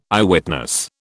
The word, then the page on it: Eyewitness: British English